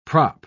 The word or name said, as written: prop